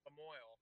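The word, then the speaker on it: La Moille: Lamoille, Illinois, oh, Jesus. – Yeah. – I tried to go French, and it's as damn as American as you.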